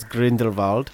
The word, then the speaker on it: Grindelwald: Yeah, the Germans pronounce it W's with a V, folks. – Yeah, so it's Grindelwald.